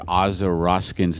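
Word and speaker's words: Aza Raskin: The way I remember Aza Roskin's name, so you guys remember it, is it rhymes with Baza Poskin.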